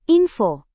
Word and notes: info: indonesian

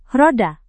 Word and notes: roda: indonesian

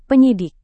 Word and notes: penyidik: indonesian

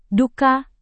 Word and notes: duka: indonesian

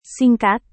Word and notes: singkat: Indonesian

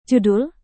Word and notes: judul: Indonesian

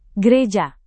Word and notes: gereja: Indonesian

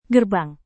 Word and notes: gerbang: Indonesian

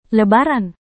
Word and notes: lebaran: indonesian

